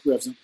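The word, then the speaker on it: Revzin: Revson, I think, is that how to say his name? It was a great show. It's always a great show. – Yeah, Revson.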